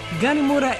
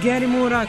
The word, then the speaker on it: Gary Moore: Parisian Walkways ili Pločici Pariza, po vašem izboru vama naj numera Gary Moore ili Gary Moore, kako god želite, mada bi trebalo bude negde između A i E njegovo ime, tako se kaže, odnosno izgovara.